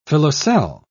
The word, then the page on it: filoselle: British English